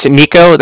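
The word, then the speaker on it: Niko: It's Nico. That's N-I-K-O. – A-N-I-K-O.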